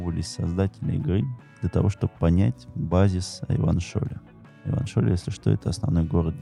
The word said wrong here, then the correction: Ревашоль: Ревашоль без Н. – А я не сказал Н. Ты ничего не докажешь. – Ревашоль, ранее классе Учись говорить правильно.